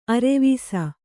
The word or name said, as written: ಅರ್ವಿಸು